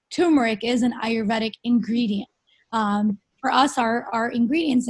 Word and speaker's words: Ayurvedic: Ayurvedic is an ancient,